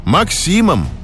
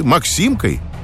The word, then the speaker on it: Максим: «Максимом» или «Максимкой» с ударением на «и».